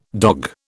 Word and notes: dog: British English

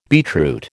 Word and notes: beetroot: American English

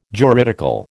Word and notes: juridical: American English

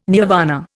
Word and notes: nirvana: Indian

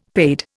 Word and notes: paid: Indian

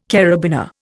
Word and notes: karabiner: Indian

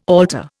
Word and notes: alter: Indian

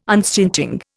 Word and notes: unstinting: Indian

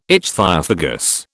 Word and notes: ichthyophagous: British English

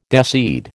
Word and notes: deseed: American English

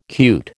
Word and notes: cute: American English

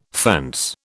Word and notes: fence: British English

